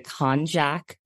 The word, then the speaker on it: Konjac: It's, I hope I'm pronouncing it correctly, the Konjac body sponge. Am I pronouncing that correctly? – Yeah, you are.